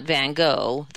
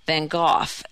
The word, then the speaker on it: Van Gogh: Hearing people say Thoreau reminds her, she said, of the scene in the film Manhattan when Diane Keaton loftily pronounces Van Gogh, not Van Gogh, the way most English speakers say it, but the Dutch way, Van Gogh, as in cough, but more phlegmy.